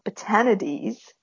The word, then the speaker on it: Batanides: Pronounced beautifully in Spanish by Rios. But she actually does appear in an episode of Next Gen. – All right. – So it's an episode where, and in that she's called Batanides, not with Spanish pronunciation, but it's a Q episode.